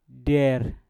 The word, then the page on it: der: Mpur